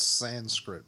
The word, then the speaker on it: Sanskrit: It's Sanskrit, by the way. – Sanskrit. – Sanskrit. – Sanskrit. – It's Sanskrit. – I don't care what kind of script it is. – It's Sanskrit. – Spell it. – I think it's S-A-N-S-K-R-I-T. It's Sanskrit. – Yeah. – That's what I said. – You said Sanskrit. Like it's written in sand.